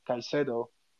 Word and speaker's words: Caicedo: And I think not only Arsenal not getting Caicedo, sorry, I keep mispronouncing his name, Caicedo, which I think was kind of an impossible deal.